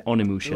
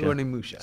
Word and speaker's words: Onimusha: Onimusha? Is that how you pronounce it? – Onimusha. – Onimusha.